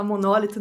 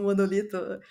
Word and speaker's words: monólito: Parece que se fala monólito, não monolito. A pronúncia, descobri um dia desses.